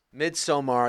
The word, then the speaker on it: Midsommar: Midsommar, I use the proper Swedish pronunciation to get the respect it deserves, because it is a movie worthy of your respect.